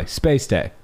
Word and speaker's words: Spay-Stay: So which I learned is spelled S-P-A-Y hyphen S-T-A-Y. Spay-Stay.